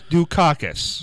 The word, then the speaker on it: Dukakis: Michael Dukakis.